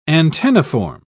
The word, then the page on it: antenniform: British English